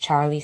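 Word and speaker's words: Charley: On Instagram and on Facebook, I'm just Charlie Sanchez. That's C-H-A-R-L-E-Y Sanchez.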